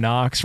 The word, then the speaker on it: Knox: The problem is my last name, Knox, rhymes with too many things that are a little bit out of bounds.